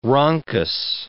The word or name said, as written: rhonchus